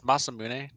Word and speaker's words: Masamune: It's Masamune. – Is it Masamune? I wasn't sure. – Yeah. So in the Japanese language, every vowel is that's correct. They don't have silent letters. Every vowel is pronounced the same every time.